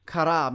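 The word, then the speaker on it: chara (χαρά): Chara means that calm gladness in the Greek. C-H-A-R-A, it's spelled.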